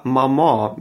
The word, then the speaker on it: Maman: Im Deutschen ist die Betonung auf der ersten Silbe und das Wort ist knackig und kurz. Ein französisches Kind sagt aber Maman mit der Betonung auf der zweiten Silbe.